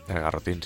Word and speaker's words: garrotín: garrotín, sí. Lo he dicho bien. garrotín, ahora, está dudando si garrotín o garrobín.